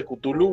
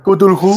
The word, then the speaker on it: Cthulhu: Uno de los mil juegos de Cthulhu, güey.